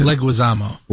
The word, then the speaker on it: Leguizamo: Who is this guy, the star of The Odyssey, John Leguizamo? Is that how you pronounce it? – Leguizamo. – Leguizamo.